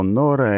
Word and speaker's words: Нора: К слову, нора — это имя такое, ударение на О.